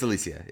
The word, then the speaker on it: Alicia: It's Alicia. Yeah. It's the Spanish pronunciation.